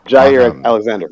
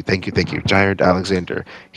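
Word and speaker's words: Jaire Alexander: Oh, Jair Alexander. Yeah. – Thank you. Jair Alexander.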